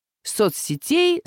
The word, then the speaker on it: соцсетей: Во множественном числе, во всех падежах, кроме именительного и винительного, ударение переносится на окончание. Соцсетей, соцсетям, соцсетями, соцсетях.